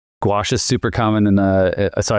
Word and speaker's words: gouache: I got some, I don't know how to pronounce it correctly, gouache. – Yeah, gouache.